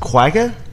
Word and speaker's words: quagga: In 1883, the last quagga, is that right? – Yeah, that's, I believe that's how it's pronounced.